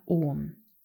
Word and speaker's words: Om: Der nächste Begriff, das Mantra OM. OM geschrieben, aber auch öfter AUM ausgesprochen, also Aum. Vielleicht hast du es auch schon mal gehört manchmal eben om, manchmal aum ausgesprochen.